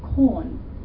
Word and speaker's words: Quorn: So I think one of the more popular ones at the moment now is something that's called corn, but it's spelled Q-U-O-R-N.